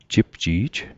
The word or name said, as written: jipji'j